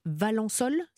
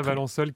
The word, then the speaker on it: Valensole: Bon, euh, on part maintenant, alors pas très loin, dans les Alpes de Haute-Provence, dans la commune de Valençol. – Oui. Ça, ça se prononce comme ça, Valençol,